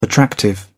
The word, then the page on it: attractive: British English